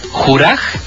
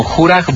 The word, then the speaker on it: Juraj: Es doctor punto Juraj, así se escribe mi nombre, Botosh, B como burbuja, O como Otto, T como Teresa, O como Otto y S como seguro. Es como Juraj Botosh.